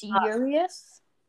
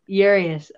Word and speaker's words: Urías: And Urías, how do you say his last name? I don't know. – I think it's Urías. – Urías. Okay, yeah.